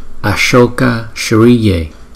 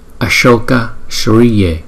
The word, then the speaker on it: Ashoka Shriye: Chúng ta phát âm là Ashoka Shriye, Ashoka Shriye, Ashoka Shriye, Ashoka Shriye. Chữ S cong chúng ta đọc là S.